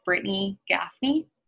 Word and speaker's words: Brittany Gaffney: If you want to add me on Facebook, my name is Brittany Gaffney. That's spelled B-R-I-T-T-A-N-Y, G-A-F-F-N-E-Y.